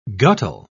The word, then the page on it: guttle: British English